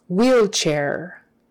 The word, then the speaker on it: wheelchair: So, W-A-R-D is pronounced ward. Ward. Ward is not really a word, so. Wheelchair. Wheelchair. The H is silent. E-E-L, C-H, and A-I-R always is pronounced air, air, wheelchair, wheelchair, accent on the first one, wheelchair. Wound, W-O-U-N-D, and this particular word, O-U, is pronounced oo, not ow, oo.